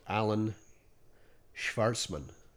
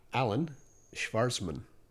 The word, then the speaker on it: Alon Shvartsman: I'm going to give a shout out to a guy called Alan Schwarzman. – Oh, yes. Say that again. – Alan Schwarzman. I'm going to spell that for you. A-L-O-N-S-H-V-A-R-T-S-M-A-N on Twitter.